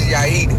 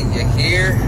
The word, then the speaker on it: Yair: It's called, my name is Yair, but white people always say Yair. Like they always say something different.